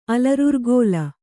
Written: ಅಲರುರ್ಗೋಲ

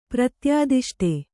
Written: ಪ್ರತ್ಯಾದಿಷ್ಟ

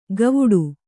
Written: ಗವುಡು

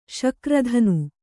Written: ಶಕ್ರಧನು

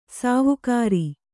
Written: ಸಾವುಕಾರಿ

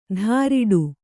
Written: ಧಾರಿಡು

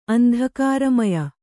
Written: ಅಂಧಕಾರಮಯ

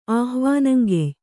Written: ಆಹ್ವಾನಂಗೆಯ್

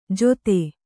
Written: ಜೊತೆ

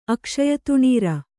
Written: ಅಕ್ಷಯತುಣೀರ

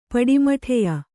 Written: ಪಡಿಮಟ್ಟೆಯ

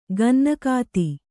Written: ಗನ್ನಗಾತಿ

